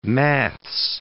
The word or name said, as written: maths